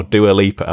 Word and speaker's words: Dua Lipa: Dua Lipa, I believe, because I tried to look it up, and all I could find was people laughing at people pronouncing it wrong. So I figured it wasn't Dua Lipa, definitely not Dua, I don't know, somebody said Lupa, I was like, I don't know where you got that from.